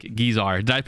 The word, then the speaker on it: Guizar: First of all, please welcome my special guest, Mike Guizar.